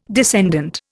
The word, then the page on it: descendant: Indian